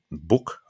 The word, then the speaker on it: Bouck: So 18 left in, on Lanzarote Ridge, led by Lieutenant Book, I think you pronounce it, B-O-U-C-K, who was only 20 years old.